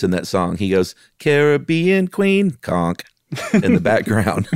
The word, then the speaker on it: Caribbean: and this conch mollusk, the queen conch is in the Caribbean, or is it Caribbean? Because I heard someone say it once. – It's the Caribbean. Yeah, you have to say it like you're about to sing the Billy Ocean song.